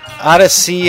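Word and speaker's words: Odisea: That was called Odyssey Link Ray, I think is how you pronounce it. I don't know. O-D-I-S-E-A. O-D-I-S-E-A, Link Ray.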